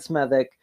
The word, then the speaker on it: Smethwick: This town, you'd assume from the way it's spelled that it's pronounced Smethwick or Smethwick, but it's actually pronounced Smethwick.